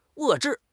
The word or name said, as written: 遏制